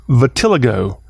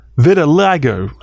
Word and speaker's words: vitiligo: But this could have been vitiligo, I think is how it's pronounced. Vitiligo, V-I-T-I-L-I-G-O.